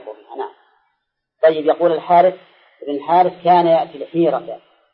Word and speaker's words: الحارث: لا ابن الحارث، لكن هم مثل الحارث ما يحطون فيها ألف، هذا ينطق بالألف ولا تكتب، مثل هذا أي هذا فيها ألف بين الها والزال، نطقا فيها ألف لكن كتابة لا.